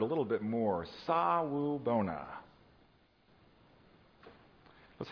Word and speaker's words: sawubona: The word is, and I'll spell it for you, sawubona. Yep, that's it. Sawubona, S-A-W-U-B-O-N-A.